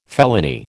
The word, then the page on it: felony: American English